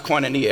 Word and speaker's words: koinonia: Now this word fellowship in the Greek is pronounced koinonia.